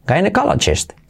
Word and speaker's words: gynecologist: Otra vez, letra clave GJ y luego I como E. cala chest. All right? gynecologist. You got it. Vamos con la frase. She was a well-respected gynecologist in the area.